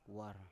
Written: war

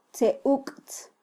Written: tzeuktz

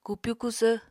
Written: kupyu kusvr

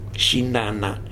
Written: jinana

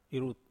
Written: rut

